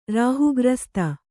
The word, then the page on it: ರಾಹುಗ್ರಸ್ತ: rāhu grasta